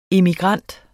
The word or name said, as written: immigrant